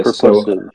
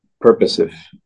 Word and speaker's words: purposive: Purposive. So. – Purposive. – It's purposive. The accent's on the first syllable, according to the dictionary. Purposive.